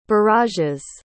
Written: barrage's

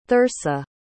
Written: thursa

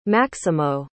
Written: maximo

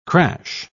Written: crash

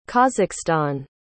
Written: kazakhstan

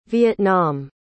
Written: Vietnam

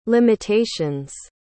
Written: limitations

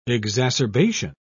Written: exacerbation

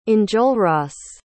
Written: enjolras

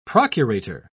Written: procurator

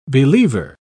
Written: believer

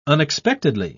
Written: unexpectedly

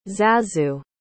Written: zazu

